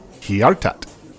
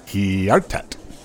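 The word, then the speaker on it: Hjärtat: Anyway, Sekundurna, that song from their EP, which is correctly pronounced Hjartat. Hjartat.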